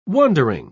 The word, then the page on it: wondering: British English